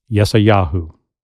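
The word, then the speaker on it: Yesayahu (ישעיהו): But in Hebrew, the name is pronounced Yesayahu.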